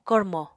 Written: kormo

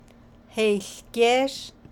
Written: haiłgéésh

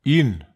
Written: in

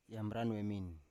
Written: yambran wemin

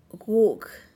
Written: guug

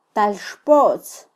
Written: talxpotz

